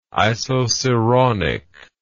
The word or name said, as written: isoceraunic